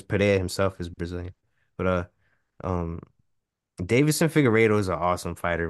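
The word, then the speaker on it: Deiveson: Like, it's spelled really weird, but it's literally just Davison. It's spelled weird, but it said Davison.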